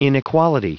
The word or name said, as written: inequality